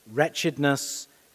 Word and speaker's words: wretchedness: wretchedness, and rescue. A few people took me to task after the 9 o'clock service about my spelling. I am aware of the silent W.